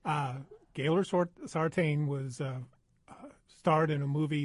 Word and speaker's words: Gaylord Sartain: It is not spelled B-I-M-B-O. I looked it up. It's a Cajun spelling. – Yes. – E-A-U-X. – Now, how do you pronounce his real name? The actor. – Well, Gaylord Sartain.